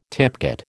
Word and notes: tipcat: American English

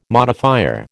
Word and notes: modifier: American English

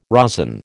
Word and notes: rosin: American English

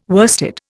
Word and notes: worsted: Indian

